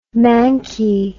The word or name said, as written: manky